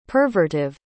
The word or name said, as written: pervertive